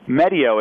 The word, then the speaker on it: Medio: Now, am I pronouncing it right? Medio or Medio? – Medio. It's Medio. Like video for medicine.